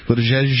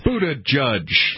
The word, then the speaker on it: Buttigieg: Buttigieg. – Buttigieg? – Yeah, sure. – Buttigieg. Yeah, something like that. – Buddha judge. Yes, that's reasonable.